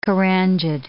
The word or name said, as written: carangid